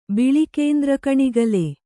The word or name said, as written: ಬಿಳಿಕೇಂದ್ರಕಣಿಗಲೆ